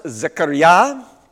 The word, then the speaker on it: Zechariah: So it's pronounced Zechariah, and it has the name element of God in there, Yah.